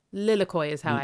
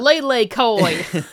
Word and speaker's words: lilikoi: Lilikoi is how I'd pronounce it. Lilikoi, okay. Lilikoi Mousse. Um, not Lele Koi.